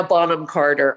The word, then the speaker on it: Bonham Carter: Helena Bonham Carter.